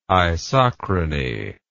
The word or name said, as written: isochrony